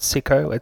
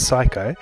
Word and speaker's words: Sycco: Not Sicko, it's Psycho. Although it's spelled S-Y-C-C-O.